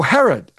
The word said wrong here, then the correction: Harrow: Okay. – No, Harrow. H-A-R-R-O-W. – Harrow. – Correct.